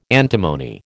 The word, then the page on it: antimony: American English